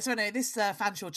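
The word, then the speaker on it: Featherstonehaugh: Written Featherstone Howe, but pronounced Fanshawe.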